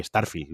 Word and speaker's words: Starfield: Bueno, Espérate que con la compra de Bethesda hace un año, eh, por ejemplo, el Starfield, el nuevo, creo, el nuevo, joder, Starfield he dicho, el nuevo de Bethesda eh, va a salir exclusivo para, Xbox, ¿no? Sí, Starfield, lo he dicho bien.